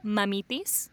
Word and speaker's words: mamitis: How do you say it? – Mamitis. – Mamipi? – Yes, mamitis.